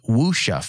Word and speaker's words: wuxia: She says, we were obviously influenced by samurai films and wuxia films, I believe is how you say that.